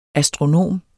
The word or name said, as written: astronom